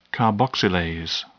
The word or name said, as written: carboxylase